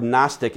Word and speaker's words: Gnostic: And the word Gnostic is spelled G-N-O-S-T-I-C, Gnostic. And we're going to see how many of y'all are as old as I am, because the G is silent, right?